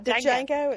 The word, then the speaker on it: Django: Well, it's spelled with a D. D-J-A-N-G-O. Which, not to be confused with. – Bingo is his. Django is his name. I don't understand. It's not Django. It's just Django.